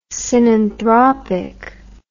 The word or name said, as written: synanthropic